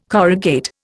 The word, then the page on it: corrugate: Indian